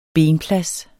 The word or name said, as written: benplads